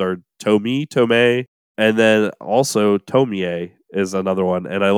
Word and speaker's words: Tomie: I'm going to go with Tomie because that was the one that was the most common one. But it's spelled Tomie. Tomie.